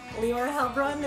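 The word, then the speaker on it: Heilbronn: My name, Leora Helbron. The people cannot pronounce? – I got it. – He got it. Ryan got it. You got the proper German pronunciation.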